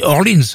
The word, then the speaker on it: Orleans: un groupe qui s'appelle Orléans, mais qu'on doit prononcer Orleans, parce qu'ils sont américains, Ambrosia, Daryl Hall & Jonotes, Al Stewart, America, Steely Dan, Bo Scaggs, Jerry Rafferty.